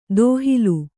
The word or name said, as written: ದೋಹಿಲ್